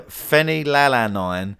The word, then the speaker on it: phenylalanine: But it's an ingredient called phenylalanine. Sorry. And f- yeah, phenylalanine and Fanny Faniline. not Fanny. No, wait, what? Yeah, yeah. It's not Britney Spears' ex-husband, Kevin oh, yeah, no, no. It's, it's actually an ingredient. It's spelled P H E N Y L A L A N I N E.